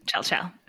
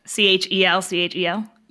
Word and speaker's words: Chelchel: Chel-Chel. C-H-E-L-C-H-E-L? – Yes. – Okay, Chel-Chel. Like that. You have to say it like that.